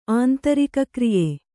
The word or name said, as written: ಆಂತರಿಕಕ್ರಿಯೆ